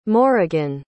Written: morrigan

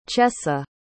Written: chessa

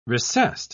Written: recessed

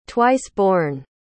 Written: twice-born

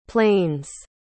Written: plains

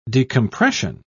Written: decompression

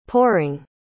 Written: poring

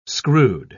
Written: screwed

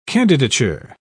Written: candidature

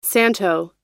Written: santo

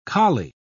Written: kali

